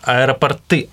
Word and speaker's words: аэропорты: Аэропорты, аэропорта – собираются... – Аэропорты. Пере... – Аэропорты да. собираются переименовывать. Песня Лагутина. Аэропорты. Там ударение можно запомнить.